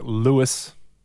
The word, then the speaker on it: Lewis: Mark Lewis Humphries. – Ooh. Lewis. How do you spell that? The French way or the other way? – It's L-E-W-I-S, so not the French way, sadly.